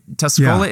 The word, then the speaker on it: Tuscola: Comes to us as a pastor from Emmanuel Lutheran in Tuscola. Am I saying that right? Tuscola, yeah.